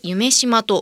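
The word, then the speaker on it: 夢洲: 命輝く未来社会のデザイン会期は再来年 2025 年 4 月 13 日日曜から 10 月 13 日月曜までの 184 日間会場は大阪市此花区の人工島夢島ドリームの夢に東京八重洲の巣と同じ巣を使って夢すと書いて夢島と読むんですね、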